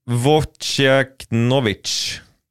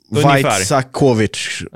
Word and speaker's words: Wojciechowicz: men Värnamos målvakt som jag inte kan uttala namnet på korrekt. – Ja, den är inte lätt. – Nej, det är Wojciechowicz. – Ja, Wojciechowicz.